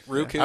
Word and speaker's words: Roku: Do you say Roku or Roku? – Roku. – Roku. I pronounce it correctly. – Yeah. Roku is not right.